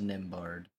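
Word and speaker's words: Nembhard: So, Andrew Nahaba. I cannot say his name. Andrew makes 2-point layup. The Celtics change Al Horford. – It's a Nimbard. Andrew Nimbard.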